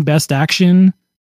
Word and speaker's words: Best Action: Like I've only picked up one Best Action figure, which BSTAXN is how you pronounce that.